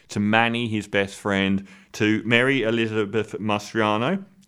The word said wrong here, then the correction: Mastrantonio: Is that her name? I always say her name wrong. Who plays his sister and things. Mary Elizabeth Mastrantonio.